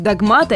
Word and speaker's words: догмат: К слову сказать, в однокоренном слове «догма» мало кто допустит ошибку. А потому, если возникают сомнения, куда поставить ударение, вспоминайте. Раз догма, значит и догмат.